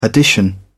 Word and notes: addition: British English

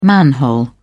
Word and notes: manhole: British English

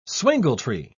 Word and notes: swingletree: British English

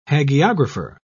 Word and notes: hagiographer: British English

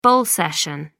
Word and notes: bull session: American English

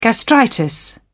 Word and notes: gastritis: British English

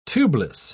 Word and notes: tubeless: British English

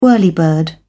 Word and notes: whirlybird: British English

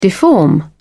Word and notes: deform: British English

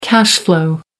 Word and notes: cash-flow: American English

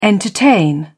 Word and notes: entertain: British English

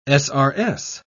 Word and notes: srs: British English

